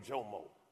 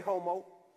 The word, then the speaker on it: Jomo: Listen, my name Jomo. J O M O. You know how long my name get butchered? Imagine being in Spanish class. Hey, homo.